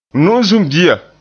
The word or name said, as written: nu'usum bia